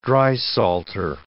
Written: drysalter

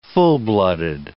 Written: full-blooded

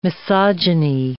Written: misogyny

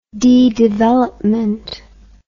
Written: dedevelopment

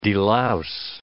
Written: delouse

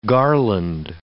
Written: garland

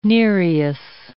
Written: Nereus

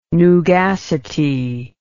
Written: nugacity

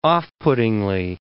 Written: off-puttingly

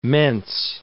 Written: mense

